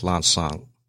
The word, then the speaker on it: Lanxang: It's in Laos, it's pronounced Lanxang. – Lanxang. – Yeah. So Lanxang sounds more Chinese.